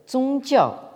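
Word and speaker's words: zongjiao (宗教): La complication vient du fait que c'est ce terme de jiao qui a été utilisé pour former un néologisme en japonais, le japonais donc shukyo, prononcé en chinois donc zongjiao.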